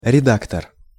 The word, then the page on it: redaktor: Russian